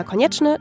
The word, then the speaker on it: Konieczny: Ich heiße bei Instagram Alina Konieczny, das schreibt man K-O-N-I-E-C-Z-N-Y.